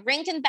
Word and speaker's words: Rankin: I keep saying Raskin and Bass, and it's not.